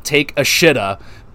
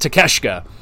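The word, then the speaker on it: Takeshita: It's spelt take a shitta, but it's pronounced Takeshka.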